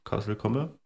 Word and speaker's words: Castle Combe: Ja, also haben dann, glaube ich, noch zwei Stunden weitergeschlafen und sind dann zum Castle Comp, Castle Combe, ja. Castle Combe.